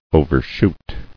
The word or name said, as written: Overshooting